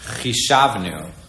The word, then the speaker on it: Chishavnu: I think it's Vich. How do you pronounce that? Chishavnu.